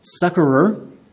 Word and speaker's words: succorer: But also, verse 2, "She hath been a succorer," and I've never been good at this word, but I looked it up, and I'm pretty sure the proper pronunciation is succorer, which is a fancy word for helper.